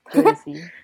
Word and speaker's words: Jodeci: Yung pala yung pronounce nun. – Jodeci. – Jodeci pala. Jodeci.